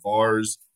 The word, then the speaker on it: VAERS: I said that while ago, but I want to talk about VAERS, or you pronounce it VARS, V-A-E-R-S.